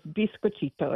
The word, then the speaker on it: biscochito: and the pronunciation is Biscochito, as you said.